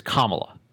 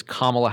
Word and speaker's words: Kamala: First of all, he is intentionally mispronouncing her name. It's Kamala. She'll tell you, anyone will tell you, it is Kamala Harris, like the punctuation mark in a law. Period. End of story.